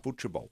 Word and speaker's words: futebol: Portuguese is futebol. – Futebol. – Futebol.